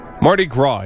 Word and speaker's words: Mardi Gras: Mardi Gras, even though it's spelled grass.